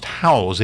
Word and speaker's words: Houzz: By the way, social media, Facebook, Instagram, YouTube, and something called, I guess it's pronounced Howzz, H-O-U-Z-Z.